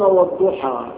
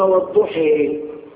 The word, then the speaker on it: الضحى: البعض يقولون والضحى، البعض لا يقرؤون هذا يقولون والضحي، البعض يقولون صراط الذين أنعمت عليهم، هكذا القرشيون يقولون صراط الذين أنعمت عليهم.